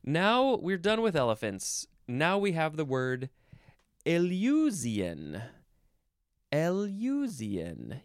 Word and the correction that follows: Eleusinian: Capital E-L-E-U-S-I-N-I-A-N. Nope, I said it wrong. Eleusinian. I don't know how I missed the emphasis there.